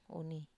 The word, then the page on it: uni: Wamesa